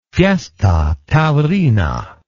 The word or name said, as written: fiesta taurina